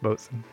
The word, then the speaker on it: boatswain: It's spelled boatswain, and it's pronounced bosun.